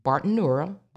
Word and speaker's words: Bartenura: The balsamic vinegar that I like is called Bartonura, balsamic vinegar of Modena, and that is pronounced Bartonura, balsamic vinegar of Modena, Bartenura, and Modena is Modena.